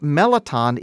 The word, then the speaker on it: mirliton: It's pronounced meliton, even though it's spelled M-I-R-L-I-T-O-N. That's the French pronunciation.